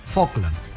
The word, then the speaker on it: Falkland: Falkland. La L no se pronuncia.